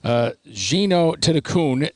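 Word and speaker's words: Jeeno Thitikul: Gino Tinakun, that's how it's pronounced now. The L is an N.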